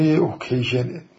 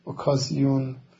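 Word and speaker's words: occasion: موقعی همین ترجمه اوکیژنه، اوکیژن یا به تلفظ فرانسوی اوکازیون، به تلفظ انگلیسی اوکیژن،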